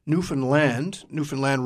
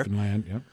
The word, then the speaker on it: Newfoundland: We have an email from Jason who writes, if you've ever been to Newfoundland rhymes with understand, by the way, as they will tell you up there. – Newfoundland, yeah.